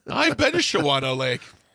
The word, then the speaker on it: Shawano: I didn't know that, but they live in Shawano because sorry, I'm a child of phonics. – No, it's pronounced Shawano.